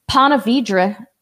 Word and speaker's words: Ponte Vedra: We're going to be at Ponte Vedra. Did I say that right this time? – Yep. – Ponte Vedra, Florida.